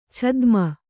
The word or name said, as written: ಛದ್ಮ